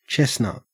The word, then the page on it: chestnut: Australian English